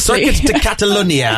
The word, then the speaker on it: Circuit de Catalunya: He edged out Mercedes teammate Valtteri Bottas at the Circuit de Catalunya in Barcelona. I hope I pronounced that correctly. – Circuit de Catalunya. I think you got it all right, Chad.